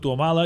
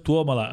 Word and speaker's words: Tuomala: Helsinki és quan Tuomala, que tothom diu Tuomala, i és Tuomala, és esdrúixola també.